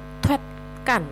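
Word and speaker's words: tuetkan (툇간): Tuetkan, ada bunyi haknya ya. Ini gabungan dari kata tue yang artinya minggir atau mundur dengan kan juga sama ya, yang artinya adalah ruangan. Nah, ketika digabung pelafalannya menjadi tuetkan.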